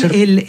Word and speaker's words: Arbeiser: Igual Arbeiser. El apellido parece ser de origen alemán, parece, me dijo una historiadora polaca que yo conocí en Varsovia, porque seguramente por ahí viene de Arbeiter, y pronunciado en yiddish cuando llegó a Polonia, a Varsovia, habrá dicho Arbeiser.